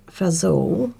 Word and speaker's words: fagioli: But, so I'll tell you how you spell it, because I would have thought that was Fagioli. – Yeah. – Anyway, it's Fagioli, Pasta Fagioli, but they've said above to pronounce it like Fazool, but I'm not going to do that.